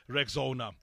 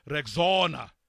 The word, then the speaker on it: Rexona: Guys, I'm sure you've seen that shield is now known as Rexona. But it appears some people don't know how to pronounce this new name. A friend even called it Rexona. But guys, it's Rexona. Like Rex and Ona.